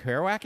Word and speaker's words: Kerouac: Going back to this Jack Kerouac, is that how you say it? – Sure. – Well, how do you say it? – Kerouac.